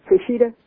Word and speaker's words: Peshitta: I may not pronounce this word right. Peshitta? – The Peshitta? Mm-hmm. – Peshitta? Is it Peshitta? – I think it is, yeah.